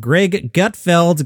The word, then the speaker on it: Greg Gutfeld: The conversation began as a discussion about Fox News late night host Greg Gutfeld.